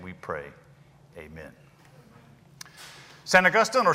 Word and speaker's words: St. Augustine: I sometimes slip off into St. Augustine just because I am a North Carolinian, and it feels good to romance your words just a little bit. But the proper way to say it would be St. Augustine. If you're going to read it in its original form, where the stress is, it is St. Augustine.